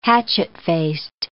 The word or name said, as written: hatchet-faced